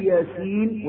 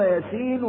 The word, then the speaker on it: ياسين: يقول لأن معلوم أن هي تنطق مش ياسين كده، لا تنطق ياسين زي ما تنطق حاء ميم، فتنطق على أنها حرفين حرفان مقطعان.